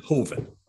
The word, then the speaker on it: Hoven: Yeah, Hoven.